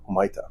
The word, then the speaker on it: Humaita: And I said it right this time, Humaita, right? Correct? – Yeah.